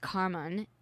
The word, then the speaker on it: karman: But seriously, as a lifelong student of yoga, karma, pronounced karman in Sanskrit, means act.